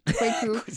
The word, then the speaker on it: Kweku: It looks really scary, but Kwaku.